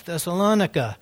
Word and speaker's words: Thessalonica: But this little man told me, he's like, why do you call it Thessalonica? It's like, oh, because that's how it's pronounced. He says, no, it's Thessalonica.